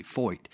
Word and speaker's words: Feucht: Sean Foyt. It's spelled F-E-U-C-H-T, Foyt.